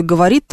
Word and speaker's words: говорит: Так вот, в слове «говорит» там должно быть не «говорит», не «говорит», не знаю, как еще, не «говорит», а вот этот неопределенный звук.